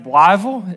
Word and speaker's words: Blytheville: It's pronounced Blytheville. If you're really from there, you can get it in one syllable.